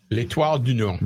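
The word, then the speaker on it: L'Étoile du Nord: So you do not pronounce the D in L'Etoile du Nord? – L'Etoile du Nord.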